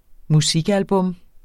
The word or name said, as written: musikalbum